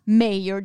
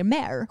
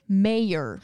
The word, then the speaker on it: Mayer: Ja, men nu visar det sig att hans namn uttalas Mayer. Det är bara alla svenska journalister och eh, kommentatorer som uttalar det fel och säger Mayer. Skämtar du med mig? Nej, vi fick en kommentar på Instagram ifrån en tjej som hade kollat med sin kille som är engelsk och han sa att man uttalade Mayer.